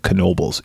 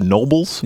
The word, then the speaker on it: Knoebels: It's called Knoebels. It looks like it should be pronounced Nobles. – Yeah. – But people around here pronounce the K because people around here are dumb.